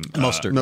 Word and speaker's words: Mostert: I forgot how to say his name. – Mostert. Uh, Mostert. Mostert. Yeah. Mostert.